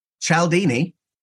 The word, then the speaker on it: Cialdini: Cialdini. – Mispronounced his name. There's an I there.